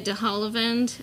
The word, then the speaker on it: de Havilland: the Errol Flynn and Olivia de Havilland, I think that's how you say her name.